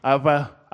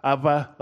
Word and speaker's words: Abba: And often in that language, a B is pronounced almost like a V. So, Abba, Abba, like that.